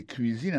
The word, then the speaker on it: cuisine: Autant de cuisine là-dedans, c'est comme ça on dit cuisine, prononcé cuisine en anglais. C-U-I-S-I-N-E. C'est cuisine.